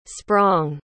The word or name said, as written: sprong